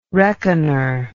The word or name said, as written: reckoner